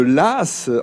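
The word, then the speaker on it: Laàs: Nous sommes toujours dans ce village de Las, en effet. Ça s'écrit L-A-A-S, mais il y a un accent grave sur le deuxième A, c'est ça, Las.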